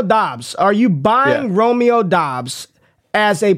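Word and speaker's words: Doubs: Romeo Dubs. Oh, Dobbs. Romeo. I keep saying his damn name wrong. Romeo Dobbs.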